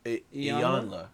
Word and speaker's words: Iyanla: How you say her name? – Iyanla. – Oh, there we go.